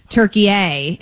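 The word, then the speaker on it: Türkiye: Okay, so it's not what we would call, you and I are making it a hard A, turkey A, and it's turkey-a, he's saying.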